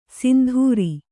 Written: ಸಿಂಧೂರಿ

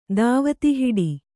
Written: ದಾವತಿಹಿಡಿ

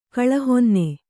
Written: ಕಳಹೊನ್ನೆ

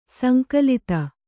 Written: ಸಂಕಲಿತ

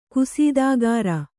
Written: ಕಸ್ತುಗಾರ

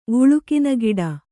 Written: ವುಳುಕಿನಗಿಡ

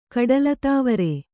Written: ಕಡಲತಾವರೆ

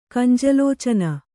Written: ಕಂಜಲೋಚನ

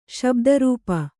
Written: ಶಬ್ದರೂಪ

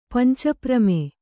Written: ಪಂಚಪ್ರಮೆ

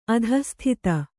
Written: ಅಧಃಸ್ಥಿತ

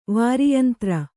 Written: ವಾರಿಯಂತ್ರ

